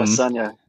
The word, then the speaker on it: Adesanya: I don't know how to say his last name. – Adesanya. – Thank you.